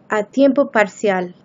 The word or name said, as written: a tiempo parcial